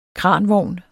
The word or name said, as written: kranvogn